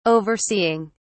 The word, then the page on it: overseeing: British English